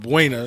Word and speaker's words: Buena: It's a place called Buena, if you pronounce it right.